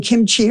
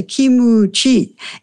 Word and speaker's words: kimchi: where J- Japanese making kimchi are not allowed in Korean hearing anyway to pronounce it kimchi, but have to say kimuchi.